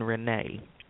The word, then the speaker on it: Rene: And actually, I've recently changed the name, trying to make it a little bit easier, but it's Sharon Renee. S-H-A-R-O-N. Renee is spelled R-E-N-E.